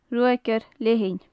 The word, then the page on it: ruagaire leathann: gaelic